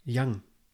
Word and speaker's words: Young: Und dann ähm, später Jan. – Young. Ah. Du hast Jan gesagt. Nee, wie, wie der Fußballer Ashley Young. – Ja, ja, aber du hast Jan ausgesprochen. Echt? Ja. Okay. Also, Young.